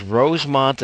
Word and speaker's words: Rowsmonte: but it was Rosemont, Illinois, between Poplar City and Crane Lake, spelled R-O-W-S-M-O-N-T-E. The E is silent.